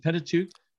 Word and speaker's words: Pentateuch: Do you pronounce it Pentateuch? – Yeah.